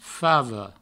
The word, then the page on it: fava: favo; Provençal